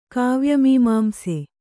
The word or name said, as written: ಕಾವ್ಯಮೀಮಾಂಸೆ